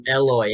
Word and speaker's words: Eloy: You called him Elroy Jimenez today. You called him Elroy Jimenez. I just didn't have the heart to correct. Oh, it's Eloy, isn't it? – Yeah, dude.